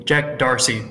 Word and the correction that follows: Dorsey: Is that a Midwestern thing? I think it's Jack Dorsey.